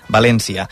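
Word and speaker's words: Valéncia: La versió en català s'haurà d'escriure amb accent tancat a la E i s'haurà de pronunciar, per tant, València.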